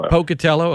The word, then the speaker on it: Pocatello: I enjoy all your work, but your piece several weeks ago when you went to, I think it's pronounced Pocatello, Idaho. – Pocatello.